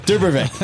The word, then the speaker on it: Dubravcic: Let's get right to it with Glenn Leest and Casey Dubro- Dubrovik. Did I butcher it, Casey? – That's a Dubravcic. That's, yeah, there you go. Dubravcic.